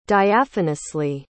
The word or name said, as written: diaphanously